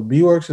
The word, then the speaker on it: B-Works: So you also like before went by like, is it B-Works? I'm guessing that's how you pronounce it. – I was producing under that name. So B-Works is what I started making music with.